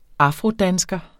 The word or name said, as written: afrodansker